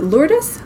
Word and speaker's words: Lourdes: It's a French city. – Yeah. – Which is pronounced Lourdes. – Yeah. – But we are, my background is Filipino and my dad's mom's family, my grandma is from Spain, and so we pronounce it Lourdes.